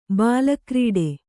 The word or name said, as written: ಬಾಲಕ್ರೀಡೆ